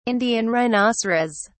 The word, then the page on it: Indian rhinoceros: British English